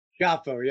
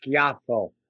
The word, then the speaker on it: Schiaffo: Schiaffo, yes. – That's Italian, right? – It sure is. The pronunciation, official pronunciation, which nobody uses, is Schiaffo, but it's Schiaffo to most Americans.